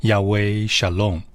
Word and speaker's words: Yahweh Shalom: 良苑讲台，亲爱的弟兄姐妹平安，今天要和大家分享耶和华沙龙，希伯来文念作 Yahweh Shalom。